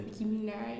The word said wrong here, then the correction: Nicki Minaj: Nicki Minaj.